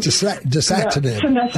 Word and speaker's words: Dasatinib: So we tried to put him on the Savinib. Is that pronounced wrong? – Dasatinib. Yes. Yeah.